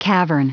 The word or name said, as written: cavern